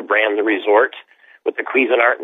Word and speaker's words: Cuisinart: Cuisinart Resort Golf. Cuisinart, is that how they pronounce it? – Cuisinart. It's the same as the people who make the cooking devices.